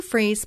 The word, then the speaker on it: Fries: Okay, so Sue is spelled S U E and my last name is spelled like fries. That's why I say Sue Freeze, spelled like fries, one word, dot com.